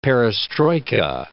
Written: perestroika

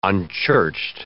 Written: unchurched

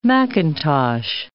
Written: macintosh

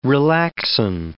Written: relaxin

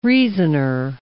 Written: reasoner

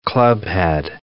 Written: clubhead

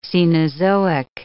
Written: Cenozoic

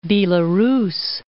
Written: Belarus

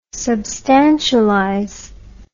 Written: substantialize

